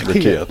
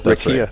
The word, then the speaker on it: RCIA: If we pronounce the acronym, it's RIKIA. – RIKIA.